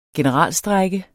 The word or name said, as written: generalstrejke